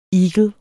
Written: eagle

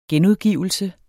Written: genudgivelse

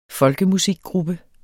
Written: folkemusikgruppe

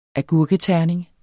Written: agurketerning

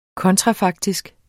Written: kontrafaktisk

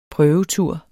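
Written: prøvetur

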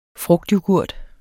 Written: frugtyoghurt

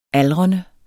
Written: aldrende

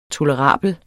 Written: tolerable

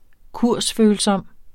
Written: kursfølsom

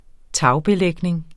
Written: tagbelægning

